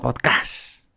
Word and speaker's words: podcast: La Real Academia de la Lengua dice que podcast, con acento en la pop, que es donde lleva la mayor fuerza de voz las sílabas de las palabras en inglés.